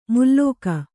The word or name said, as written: ಮುಲ್ಲೋಕ